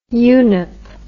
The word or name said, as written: unep